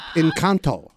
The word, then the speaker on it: Encanto: You're Encanto. – That is not the right pronunciation. – Whoa. – It is pronounced dummy Encanto. – Can you pronounce it? – Encanto.